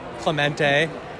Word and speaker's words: Clemente: Clemente. – That's right. That's exactly how it's supposed to be pronounced.